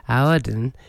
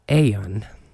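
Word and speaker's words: Aodhan: It's A-O-D-H-A-N. That's the spelling. So I've been saying Auden. It's actually an Irish Gaelic name, and you pronounce it something like Aeon.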